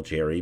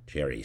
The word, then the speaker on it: Xerri: So you could check him out on LinkedIn, Daniel Cherry, but it's spelled X-E-R-R-I, Daniel Cherry.